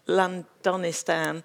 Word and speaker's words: Londonistan: Londonistan.